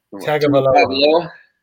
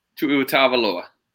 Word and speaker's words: Tagovailoa: Tagovailoa. – How you say? – Tua Tagovailoa.